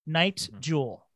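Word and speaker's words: Nite Jewel: It's called Night Jewel, but it's spelled NITE. Night Jewel.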